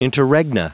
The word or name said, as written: interregna